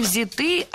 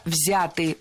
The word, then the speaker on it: взяты: Не взяты, а взяты. Ударение в этом слове сохраняется на первом слоге. Он взят, оно взято, они взяты. И только лишь в женском роде ударение переносим на окончание.